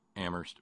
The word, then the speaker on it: Amherst: Stay warm, Kitty in Boston and Terry in Amherst, where only the H is silent.